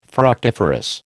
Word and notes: fructiferous: American English

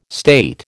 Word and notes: state: American English

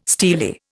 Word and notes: steely: Indian

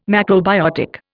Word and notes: macrobiotic: Indian

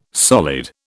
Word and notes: solid: British English

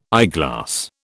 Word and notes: eyeglass: British English

